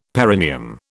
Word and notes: perineum: British English

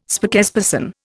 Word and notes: spokesperson: Indian

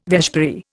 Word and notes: vespiary: Indian

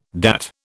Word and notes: DAT: British English

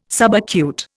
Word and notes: subacute: Indian

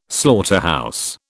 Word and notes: slaughterhouse: British English

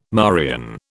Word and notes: Marian: British English

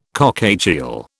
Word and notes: cockatiel: British English

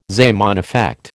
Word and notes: Zeeman effect: American English

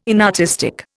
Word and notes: inartistic: Indian

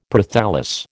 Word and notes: Prothallus: American English

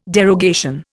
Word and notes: derogation: Indian

